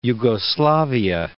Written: Jugoslavia

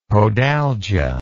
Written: podalgia

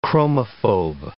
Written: chromophobe

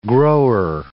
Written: grower

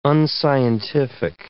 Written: unscientific

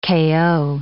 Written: kayo

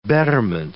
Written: betterment

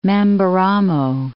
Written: Mamberamo

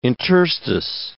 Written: interstice

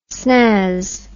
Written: snazz

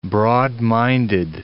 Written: broad-minded

